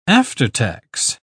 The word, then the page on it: aftertax: British English